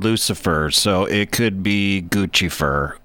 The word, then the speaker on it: Guccifer: Guccifer, dude. Get it right. It's Guccifer. – Yeah, obviously it rhymes with Lucifer.